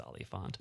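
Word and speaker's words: Olyphant: Is that how you pronounce it? – Oliphant. – Oliphant. – Oliphant. – Really? – I believe it's Oliphant. – Interesting.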